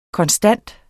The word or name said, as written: konstant